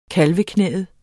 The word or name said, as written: kalveknæet